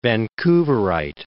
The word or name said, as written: Vancouverite